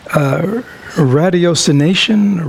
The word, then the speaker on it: ratiocination: Is it ratiocination? Ratio? R-A-T-I-O-C-I-N-A-T-I-O-N? – Ratiocination. – Is that a guess or do you know it? Rash, So it's rash, not rat.